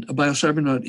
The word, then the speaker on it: biocybernaut: Rhymes with astronaut, and a biocybernaut is to inner space what a bio, what an astronaut is to outer space. And so it's spelled similarly, BIOCYBERNAUT. That suffix, not, N-A-U-T, is a Greek suffix, and it means somebody who goes on an adventure.